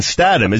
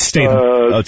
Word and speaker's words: Statham: Did I mispronounce his name? – Yeah, you both did. It's Statham.